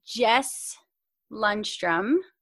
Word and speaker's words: Jess Lundstrom: I have Miss Jess Lundstrom.